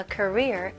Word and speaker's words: career: I'm going to pursue a career in medicine. He's at the peak of his career. Career, 직업, 직장 생활입니다. Career, 끝까지 R 발음 해주셔야 돼요.